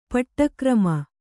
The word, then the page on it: ಪಾಠಕ್ರಮ: pāṭha krama